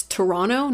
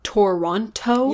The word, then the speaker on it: Toronto: Also, I think it's very suitable and right to mention here that it's Toronto, not Toronto. – Yeah, you don't pronounce the second T.